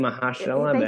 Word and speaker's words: Mahershala: I keep saying Mahershala. – It rhymes with Ursula.